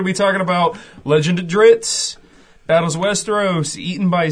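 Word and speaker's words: Drizzt: Dritz. – Wow. – I think it's Dritz.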